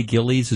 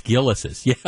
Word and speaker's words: Gilles: I really do, because I used to say Gilly's as well. It's actually, it's pronounced Gillis's. Yeah. Yeah. But I mean, right. So I love the place too, and it's spelled, you know, Gilles. And everybody says it's Gillies, but it's actually pronounced Gillis's, as opposed to Gillies.